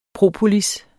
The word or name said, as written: propolis